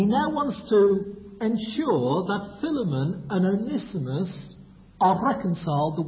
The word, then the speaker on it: Philemon: Go back to Philemon, or for those who prefer the Australian way of pronouncing it, Philemon.